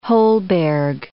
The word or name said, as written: Holberg